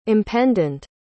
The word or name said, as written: impendent